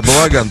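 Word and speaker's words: Балогун: Вот у них два мяча забил во встрече с Парагваем Балаган или Балоган его, я не знаю, как правильно ударение. Я его Балаган всю жизнь называю. Но он молодой достаточно. – Первый вариант. – Да, Балаган пойдет.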